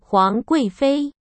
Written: Huang Guifei

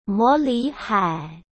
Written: Mo Lihai

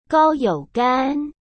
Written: Gao Youqian

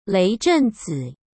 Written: Leizhenzi

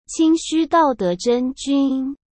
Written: Qingxu Daode Zhenjun

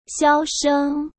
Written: xiaosheng

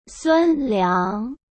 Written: Sun Liang

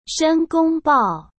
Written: Shen Gongbao

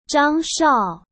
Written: zhangshao

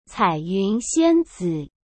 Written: caiyunxianzi

